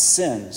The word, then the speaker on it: Sin: It just so happens that that Hebrew word is pronounced sin.